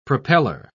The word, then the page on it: propellor: British English